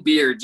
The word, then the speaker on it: Beard: Yes, Ben Beard, just like facial hair. – Sorry about that. – No problem. Yeah, my name is Ben Beard.